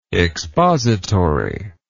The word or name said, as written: expositively